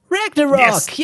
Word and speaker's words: Ragnarok: Ragnarok. – For the listeners, that is how you pronounce it.